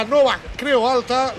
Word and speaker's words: Nova Creu Alta: debut en casa en la Nova Creu Alta, lo he dicho bien, ¿no? – Sí, Nova Creu Alta, bueno, cada vez más complicado esto.